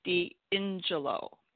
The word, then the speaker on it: D'Ingillo: And just so you know, my last name is pronounced D'Angelo.